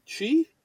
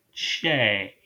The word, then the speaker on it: Che: I think it's pronounced Chi? – Che. – Che? – Che. – Che. – Che. – Okay.